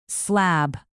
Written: slab

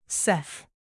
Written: seth

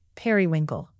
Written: periwinkle

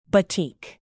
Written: batik

